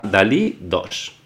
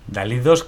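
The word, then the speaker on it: DALL-E 2: Esta herramienta se llama Dalí 2, ¿vale? Pero hay más herramientas. – Dalí 2 que se escribe Dalle 2, o sea, es como en inglés hay el juego, ¿eh?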